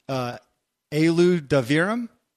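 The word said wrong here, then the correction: Eilu D'varim: How close am I? – Close. Divarim. – Divarim.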